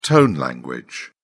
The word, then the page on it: tone language: British English